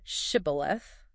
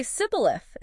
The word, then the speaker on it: shibboleth: He asks them to pronounce the word shibboleth. – How did that work? – Well, because of their original dialect, the Ephraimites couldn't pronounce it correctly. Oh. It would say sibboleth instead.